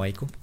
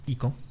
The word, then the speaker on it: Ico: Te pronuncia Ico, no Aiko, Ico.